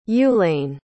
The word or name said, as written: ulane